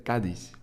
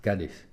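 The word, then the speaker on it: Cádiz: Het is dus het accent op de A dat ervoor zorgt dat je... – Ja, en je mocht dus ook niet de dis te hard... – Nee, daar mag je zeker de klemtoon. Nee. Dus dat deed ik dan verkeerd, dus ik zei Cadiz, maar het is meer Cadiz. – Cadiz. – Ja. – Cadiz. – Maar niet te lang de A ook.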